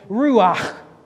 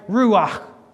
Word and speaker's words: ruach: This is how you say ruach. There's got to be something coming out of your mouth. You're not saying it right. Ruach. Ruach. There's got to be a breath behind that word, because again, that word means the breath of God.